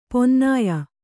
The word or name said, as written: ಪನ್ನಾಯ